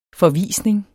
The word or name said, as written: forvisning